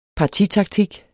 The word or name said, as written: partitaktik